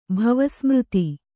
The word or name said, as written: ಭವಸ್ಮøತಿ